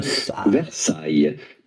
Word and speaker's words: Versailles: Versailles. The S at the end of Versailles is definitely silent, so do not pronounce it.